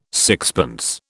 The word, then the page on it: sixpence: British English